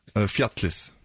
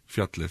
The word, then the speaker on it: Fjallið: How do you say the mountain in Icelandic? – Fjallið. – Fjallið? – Fjallið.